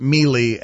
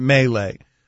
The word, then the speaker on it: melee: The conversation we were having yesterday about this, and thank you to all of you who corrected me that I pronounced it Melee and it's Melee.